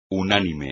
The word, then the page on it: unánime: espagnol